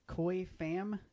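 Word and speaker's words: Khoi Pham: We've got art by Koi Pham. Am I saying this right? – Yes, I believe that's how you say it.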